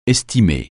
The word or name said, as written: estimer